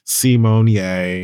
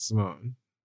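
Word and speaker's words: Symoné: It's Raven Simonier. – Oh, it really is that? – Yeah, and not Simone.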